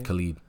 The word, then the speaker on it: Khalid: Khalid.